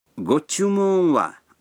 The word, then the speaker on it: ご注文は: Гочумун ва означает «что бы вы хотели».